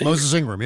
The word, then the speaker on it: Moses Ingram: Moses Ingram? I think. – Moses Ingram. Yeah. Yeah.